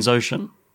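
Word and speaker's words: Xhosa: So he so the accent is Zosha. I'm probably butchering that pronunciation, but it's X-H-O-S-A. And that guy is Zosha in real life.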